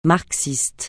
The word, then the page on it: marxiste: francais